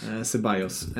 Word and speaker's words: Ceballos: Mål av, nu uttalade jag hans namn senast riktigt illa, Caballeros. – Ceballos. – Alltså jag är helt ute och cyklar. – Ja. – Han får mig ur balans, Ceballos.